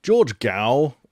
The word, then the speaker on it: George Gao: George Gao, which is how you actually pronounce it, was there, and he served as director of the Chinese Center for Disease Control and Prevention from August 2017 to July 2022.